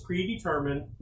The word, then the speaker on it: predetermined: Calvinism says that you were whom gets it into Christ was predetermined, predetested. Predetermined, I said that wrong.